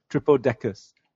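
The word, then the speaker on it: Tripledacus: Basically, it's good luck trying to find Tripleticus. I don't like to say this name. – Tripleticus? – Tripleticus. – The Japanese version, the Japanese pronunciation for it is just Tripodecus. Like triple decker.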